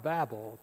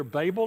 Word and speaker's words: Babel: And I say all that as to precede what I'm going to say about the Tower of Babel, okay? Curious enough, you can pronounce it either Babel or Babel, either way, and both are correct, no matter how you've heard it.